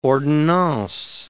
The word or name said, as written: ordonnance